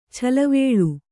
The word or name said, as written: ಛಲವೇಳು